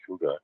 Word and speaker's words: hygge: It's spelled H-Y-G-G-A-E, but pronounced hygge, mm-hmm.